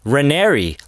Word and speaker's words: Raniere: it's Raniere, like canary," and she was like so proud of herself, and I'm like, "You're totally brainwashed, you stupid hoe."